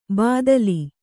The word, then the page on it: ಬಾದ್ಲಿ: Kannada